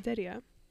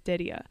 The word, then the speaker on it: Derya: So now it's spelled D-E-R-Y-A, as you see now, as Daya Didia.